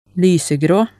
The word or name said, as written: lysegrå